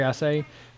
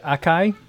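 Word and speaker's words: açaí: Martha says, for years I pronounced it acai berries until I heard it pronounced with a soft C.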